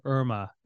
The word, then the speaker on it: IRMAA: Another priority that we see all the time is staying under IRMA. It's spelled I-R-M-A-A.